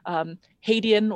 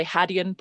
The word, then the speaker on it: Hadean: It probably lived sometime between the end of the Hadean, or the Hadean, both are correct pronunciations, eon.